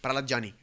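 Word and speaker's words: Prahlad Jani: Se escribe en, español J-A-N-I. – Pralad Jani.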